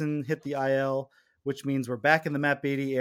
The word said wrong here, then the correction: Beaty: Or Matt Beaty, it's Beaty. I got, finally got his name right.